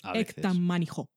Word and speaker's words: Äkta människor: También tenemos una serie sueca que se llama Acta Manis score así se escribe, con cas por ahí, pero que he buscado la pronunciación sueca, porque a veces jugamos a ser profesionales aquí. Y es Ektamanijo. ¿Cómo? – Ektamanijo.